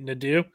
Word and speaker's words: Nadeau: And I thought it was funny when Ryan Nadeau was talking, or Brian, how do you say it? Nadeau. – Yes. Ryan Nadeau. Yes. The proper French pronunciation.